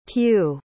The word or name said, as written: pugh